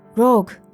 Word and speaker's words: Rogue: Camilla und Rogue?« »Es wird Englisch ausgesprochen. Rogue«, korrigierte ihn seine Kollegin.